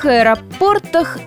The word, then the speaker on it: аэропорт: Приятная новость в том, что ударение в единственном и множественном числах падает абсолютно одинаково. А значит, будут аэропорт, аэропорты. Перелететь из аэропорта в аэропорт, вести речь об аэропорте и так далее. Ударение падает на порт.